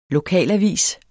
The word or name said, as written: LOKALAVIS